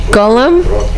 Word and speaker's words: Golem: So the scene where Phoebe meets Frankenstein is also the scene from the Golem.